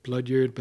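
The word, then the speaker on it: bloodyard: It's supposed to be like vineyard, blood yard, but then I decided it should just be pronounced blood yard, but still one word.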